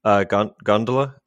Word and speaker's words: Gandler: She literally just got me the pronunciation of her last name, Gandler.